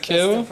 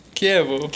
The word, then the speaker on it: Chievo: And I have to apologize to the Italian community because I kept calling my formation Chivo, where it's actually Chievo, I think. – Yes, right? – Chievo? That's the pronunciation.